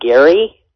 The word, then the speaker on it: Gehry: The architect, Frank Gehry, is that mm-hmm. how it's pronounced? – Mm-hmm.